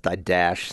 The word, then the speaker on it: Didache: It's spelled D-I-D-A-C-H-E. It's usually pronounced Didache. Some say Didache.